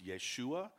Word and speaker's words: Yeshua: In Hebrew, Joshua is pronounced Yeshua.